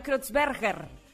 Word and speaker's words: Kreutzberger: Oye, pero muy bien pronunciado el apellido de don Francisco. – Sí, porque yo lo ensayé años. Ay, sí. – Mario qué? – Mario Kreutzberger.